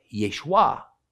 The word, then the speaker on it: Yeshua: So in the Galilee, the way his name was spoken sounded like Yeshu. In Judea, it sounded like Yeshua.